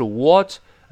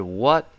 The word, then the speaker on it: what: 这个 what what 注意美式发音，口型还是略大一些，不是 what。而是 what what 读快之后，后面那个 t 失去爆破了，因为这个 t 爆破音碰见别的辅音了啊。